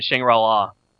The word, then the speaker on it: Shangri-La: And then Shangri-La,